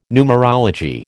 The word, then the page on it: numerology: American English